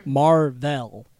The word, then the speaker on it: Mar-Vell: Just Mar-Vell. – Yeah. – Sure. – Like, if you say it like it's two names.